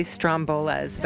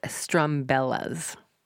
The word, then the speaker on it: Strumbellas: Sorry, I always say Strombolas, but it's not Strombolas, it's Strumbellas.